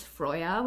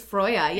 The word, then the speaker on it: Freyja: So first and foremost, the medieval Norse pronunciation for her name is Freyja, which I sometimes... – Freyja? – Is Freyja, yes.